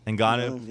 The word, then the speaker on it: Ngannou: Like, the next time we see Ngannou, is that how you say his name? – Yeah. – Ngannou.